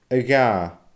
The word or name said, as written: a dhà